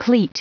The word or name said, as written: cleat